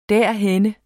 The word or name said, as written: derhenne